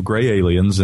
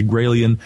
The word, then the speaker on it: Gralien: there are so many folks who say gray alien, they can't pronounce Gralien, but yes, it is Gralien Report.